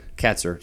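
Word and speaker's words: Kaczor: They held Carolina around 250 yards for the second week in a row, and I think this is damning for Nate Kazor, the special teams coordinator. – Katzer. It's spelled really crazy, but yeah, looks like Kazor, but we'll call him Katzer.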